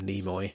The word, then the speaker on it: Nimoy: Nimoy, I should call him Nimoy. – You should. – That's how one pronounces it.